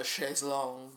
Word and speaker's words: chaise longue: well, you gotta love a chaise longue. That's the French pronunciation.